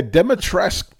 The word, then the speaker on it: Dimitrescu: Yeah, Dimitrescu, by the way. That's how you say her name. I was like, oh, there's no ooh in there. Dimitrescu? No, just Dimitrescu.